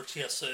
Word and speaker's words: Chiesa: I think that Chelsea... How do you say it? – Chiesa. – Gee, my goodness. Chiesa, I think he's hungrier.